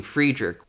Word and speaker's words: Friedrich: But he pronounces his name Friedrich, which is the German pronunciation.